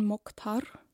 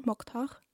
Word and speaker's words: Moktar: It's written Mokhtar. M-O-K-T-A-R. Mokhtar. I will not always, I hope I pronounce it right, but I think it's right because Mokhtar means in Arabic the chosen one.